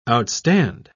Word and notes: outstand: British English

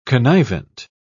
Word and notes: connivent: British English

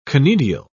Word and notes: conidial: British English

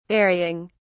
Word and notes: varying: British English

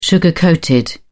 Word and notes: sugar-coated: British English

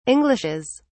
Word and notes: englishes: British English

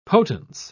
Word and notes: potence: British English

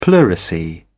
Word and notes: pleurisy: British English

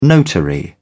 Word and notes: notary: British English

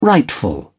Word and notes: rightful: British English

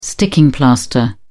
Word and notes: sticking plaster: British English

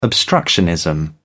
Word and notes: obstructionism: British English

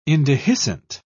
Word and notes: indehiscent: British English

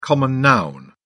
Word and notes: common noun: British English

